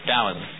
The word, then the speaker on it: Taoism: And Taoism is spelled Taoism with a T, but it's pronounced with a D sound, Taoism.